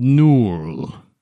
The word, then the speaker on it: Knurl: My father was Lord Turaliton Zalhonin, ruler of a faraway land, a land called Nurl. That's spelled K-N-U-R-L.